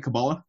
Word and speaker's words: Kabbalah: Kabbalah? – Yeah.